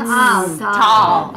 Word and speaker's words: Taal: It's Taal. – Okay, yeah. Sorry. – I said, not Taal volcano. – No, our pronunciation is not good.